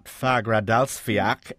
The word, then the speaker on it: Fagradalsfjall: We've all seen the incredible TV images of lava flowing from an erupting Mount Fagradalsfjall. Is that how you pronounce it, or something like it? – Yeah, it's kind of close, yeah. Yep.